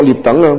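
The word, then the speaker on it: 躐等: 心无障即所学猎等。何为猎等呢？这个猎字呢啊，打猎的猎字，左边那个就改为这个足，这个脚啊，啊这个足字边，读作猎等啊。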